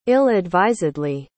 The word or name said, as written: ill-advisedly